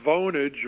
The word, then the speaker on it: Vonage: It's a very serious question here because I think it's Vonage rhymes with Bonage.